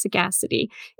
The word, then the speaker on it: sagacity: But then when I read the definition and I heard it, the actual pronunciation mm-hmm. is sagacity, it made so much more sense.